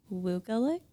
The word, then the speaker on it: Wukelic: And we have Jennifer Wukelic.